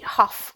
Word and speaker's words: Hoff: And we will run very quickly to Gary Hoff, which is pronounced like cough, from South Africa.